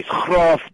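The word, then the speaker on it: Graff: Graph diamonds, and I don't know if I'm pronouncing it right. Somebody said it's graph. Yes, Graf Diamonds,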